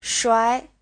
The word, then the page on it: shuai: shuai2